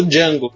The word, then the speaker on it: Django: Eu falo Django também. Django é, é, parece que é assim que ele pronuncia no filme.